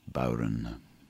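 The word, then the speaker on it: bodhrán: The instrument I play is a bowron, spelt B-O-D-H-R-A-N, but pronounced bowron.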